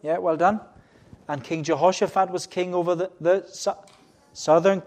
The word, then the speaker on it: Jehoshaphat: Jehoshaphat, I had to practice a lot so I wouldn't get it wrong.